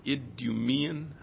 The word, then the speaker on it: Idumean: He was an Edomite, and I don't know how to pronounce this word, Idumean. Matt, is that okay? – Yeah.